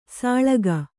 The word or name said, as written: ಸೊಳಗ